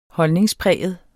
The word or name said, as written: holdningspræget